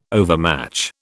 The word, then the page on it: overmatch: British English